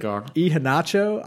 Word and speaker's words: Iheanacho: We got an 89th minute goal by this 18-year-old Nigerian, Iheanacho. I'm pretty sure is how you pronounce his name.